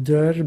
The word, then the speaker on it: Derw: They're called Der, but it's spelt D-E-R-W. It's Welsh.